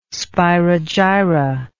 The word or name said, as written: spirogyra